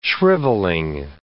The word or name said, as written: shrivelling